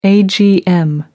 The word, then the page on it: agm: American English